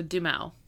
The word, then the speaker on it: Duhamel: Duhamel. – Like Josh Duhamel, the actor? – Well, okay. – Josh Duhamel. – Oh, okay. So Duhamel.